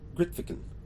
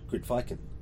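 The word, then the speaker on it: Grytviken: which is pronounced Gritviken, not Gritviken, which makes very little sense when you see it written down my script, but hopefully I'll be getting that one correct from now on.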